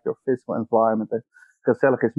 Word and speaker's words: gezellig: gezellig. – Gezellig. – Gezellig. – Gezellig. – Yes, you have to pronounce it like you just had a herring, and then you try to say it.